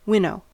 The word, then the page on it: winnow: American English